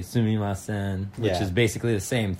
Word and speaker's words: sumimasen: But sumimasen is a word where there's a certain nuance in the way it's said. It's like a very nasally sumimasen.